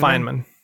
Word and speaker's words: Feynman: Feynman.